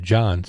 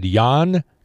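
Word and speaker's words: Jann: Jan Wehner, W-E-N-N-E-R, Wehner, careful. The Californian, whose first name is pronounced Jan. I was worried about the second name, but first name is Jan, actually. I said John, so Jan.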